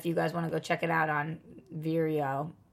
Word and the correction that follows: Vero: How do we say it? – Vero.